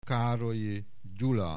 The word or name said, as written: Károlyi Gyula